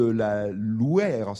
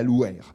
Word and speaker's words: Loyre: la Louère, c'est ainsi qu'on prononce à peu près le nom de ce cours d'eau qui s'écrit L-O-Y-R-E, donc ce n'est pas la Loire, hein, c'est la Louère.